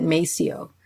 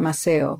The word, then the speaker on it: Maceo: They generally pronounced it Maceo, not Maceo,